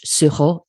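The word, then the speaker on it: sureau: And for my listeners who are not located in France, Suho, the company is spelled S-U-R-O, but that also is the word, not the same spelling, for elderflower in French. Suho is how you say elderflower.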